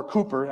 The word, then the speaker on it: Cowper: Cooper, I think it's pronounced Cooper, though it's spelled Cowper, C-O-W-P-E-R.